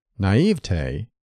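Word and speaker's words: naïveté: These four syllable variants are illogical and erroneous. Naivete combines naive and te, and standard word division calls for three syllables, naive te. Don't add a spurious fourth syllable. It is acceptable to stress the middle syllable, naive te, but the weight of authority favors the primary accent of the final syllable, naive te, which should rhyme with day, never with t.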